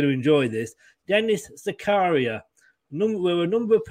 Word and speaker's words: Zakaria: First of all, I think his name's pronounced Zakaria.